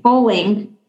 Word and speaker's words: Folling: And Folling rhymes with bowling.